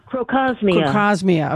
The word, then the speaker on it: Crocosmia: Another one that I have just fallen in love with, and it's pretty inexpensive, that Croxomia, did I say that right? Croxomia? – Crocosmia.